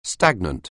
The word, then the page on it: stagnant: anglais